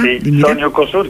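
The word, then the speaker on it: Sonny Okosun: E noi sfumiamo Sanne Okosum, oppure come si pronuncia? – Sonny Okosur, che poi il brano è Which Way Nigeria, che certo.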